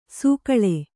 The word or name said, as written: ಸೊಗುಳಿ